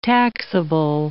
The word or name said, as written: taxable